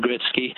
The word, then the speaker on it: Gretzky: Wayne Gretzky has always been pronounced Wayne Gretzky,